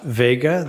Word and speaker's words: Vega: My full name is Russell Scott Vega. The last name is spelled V-E-G-A.